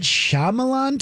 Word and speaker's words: Shyamalan: Night Shyamalan twist.